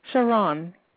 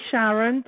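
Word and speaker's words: Sheron: It's actually Sharon, but it's S-H-E-R-O-N.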